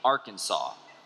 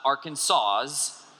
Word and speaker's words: Arkansas: According to their strict code, the proper pronunciation is in three syllables with the final S being silent. It is not Arkansas's, even though it looks like that. It is Arkansas. And the A in each syllable with an Italian sound, the accent on the first and last syllables.